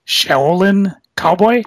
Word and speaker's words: Shaolin Cowboy: I got a couple of the, how do you say it, Shaolin Cowboy? – Yep.